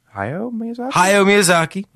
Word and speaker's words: Hayao Miyazaki: Hayao Miyazaki.